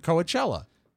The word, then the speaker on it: Coachella: Not Coachella. – I say it like Bugs Bunny. Is that wrong? – It's yes. – It's Coachella.